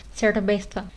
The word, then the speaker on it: cerveza: Então, por exemplo, a palavra cerveza, que é cerveja, na Espanha as pessoas costumam é, pronunciar ao momento de falar a Z, colocar a língua para fora. Então a pronúncia seria alguma coisa mais parecida como cerveza a ideia seria alguma coisa mais ou menos como pôr a língua para fora e falar o sa. Então, fa, fa.